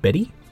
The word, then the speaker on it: Bette: So, here we have the slightly controversial pronunciation of B-E-T-T-E. – Is it Betty? – In this song, yes.